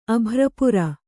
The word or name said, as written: ಅಭ್ರಪುರ